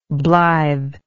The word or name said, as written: blitheness